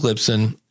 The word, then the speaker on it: Libsyn: Z-Man is, and I wonder if he did this on purpose, I'm leaning towards LibSync. It's actually Lisbon. Actually, I said it wrong again. That's the funny part about it. These are the two you hear, Lisbon and LibSync. It's Libsyn.